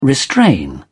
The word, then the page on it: restrain: British English